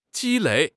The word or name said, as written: ji1 lei3